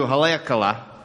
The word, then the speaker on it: Haleakala: So we go up to Haleakala, which I have surprisingly pronounced correctly.